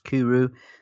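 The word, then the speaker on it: Cwrw: This one says that it's pronounced Kuru, meaning beer in Welsh.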